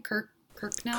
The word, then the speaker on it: Kirkconnell: How do I say her name? Kirkconnell? – Kirkconnell. I've heard Kirkconnell.